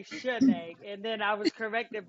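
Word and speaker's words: Chiney: And then you have, excuse me if I pronounce her name wrong, Penny Shinny Ogumike. – Chinay Ogumike, yep.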